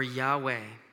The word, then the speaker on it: Yahweh: In Hebrew, the way you'd pronounce this is either two ways. You can do it either way, V'yavah or Yahweh.